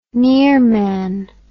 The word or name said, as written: nearman